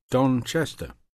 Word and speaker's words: Chester: Don Chester. – How do you spell that? – C-H-E-S-T-E-R.